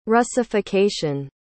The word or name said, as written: russification